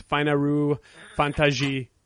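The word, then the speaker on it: Fainaru Fantasy (ファイナルファンタジー): Actually, in Japanese, and I'm going to hack this. So, Fortengard, okay. Just be prepared to hear nails on the chalkboard, but it's Fainaru Fantasy 10 is how they actually pronounce it out.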